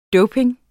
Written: doping

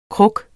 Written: kruk

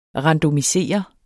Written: randomisere

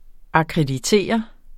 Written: AKKREDITER

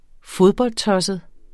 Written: fodboldtosset

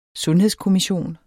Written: sundhedskommission

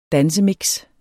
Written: dansemix

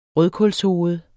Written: rødkålshoved

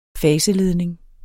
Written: faseledning

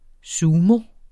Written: sumo

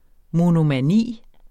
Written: monomani

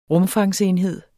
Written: rumfangsenhed

